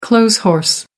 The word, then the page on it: clothes horse: American English